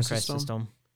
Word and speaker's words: Chrysostom: Chrysostom? How do you say it? – Yeah. – John Chrysostom.